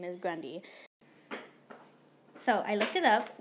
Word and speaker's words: Grande: Like apparently Ariana Grande, her last name's Grundy. But everyone just pronounces it wrong.